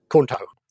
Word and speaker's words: KHUNTO: And then we have, it's spelt as an acronym, K-H-U-N-T-O. – Kunto.